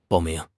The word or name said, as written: bao4 ming2